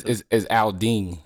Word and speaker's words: Aldin: It's Aldin. – Aldin. – So, but okay. Everybody pronounces Aldin, but you can call me Dean.